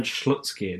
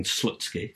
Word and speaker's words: Slutsky: It's fairly safe to say that the big name gamble of bringing Leonard Slutsky in is proving to be just an opportunity to laugh like children at his name.